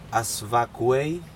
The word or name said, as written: asvakuei